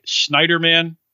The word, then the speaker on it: Schneiderman: It's, I mean, to talk about another attorney general doing some pretty bad and evil things, this Eric Schneiderman, am I saying his name right, from New York, New York's attorney general.